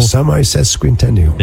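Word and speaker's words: semisesquicentennial: Semi-sesquicentennial.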